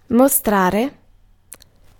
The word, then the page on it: mostrare: /mɔ.ˈstra.re/